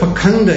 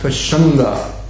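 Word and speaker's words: pāṣaṇḍa: The word pakanda used in this verse is sometimes pronounced pashanda.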